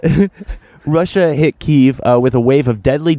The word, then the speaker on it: Kyiv: Am I saying that right? In Ukraine? – You did. I was corrected harshly by the news junkie when I tried to tell him it's pronounced Kiev, and he said, Actually, that's wrong.